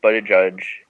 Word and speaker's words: Buttigieg: So I think that, Mayor Pete Buttigieg, I think I learned how to pronounce his name correctly.